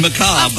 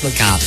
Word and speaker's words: macabre: It's actually macabre. – Of course it's macabre.